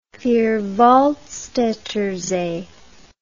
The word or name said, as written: vierwaldstattersee